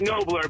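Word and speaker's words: Knobler: Well, it's actually pronounced Nobler, but I get Nobler all the time. No worries. – It's got to be. I mean, it's K-N-O-B-L-E-R.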